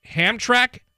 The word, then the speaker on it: Hamtramck: I mentioned in Detroit, it, it's Ham. I think I'm, I'm mispronouncing the name. It's Hamtramck Stadium in Detroit, one of a handful of Negro League ballparks still standing in some capacity.